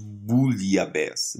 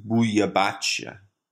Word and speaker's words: bouillabaisse: So when I talk about bouillabaisse, the French pronunciation of which is bouillabaisse, although in Occitan it's pronounced bouillabache.